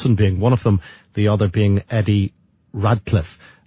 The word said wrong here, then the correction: Ratcliffe: And Ratcliffe is his, the pronunciation of his surname.